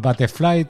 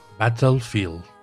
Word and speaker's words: Battlefield: y como lo tengo, como estoy con el síndrome Netflix ese, con el, con el otro tengo el Battlefly también, que, me lo recomendaste. No sé si lo he dicho bien, ya lo sabéis. Y quiero probarlo. – Battlefield.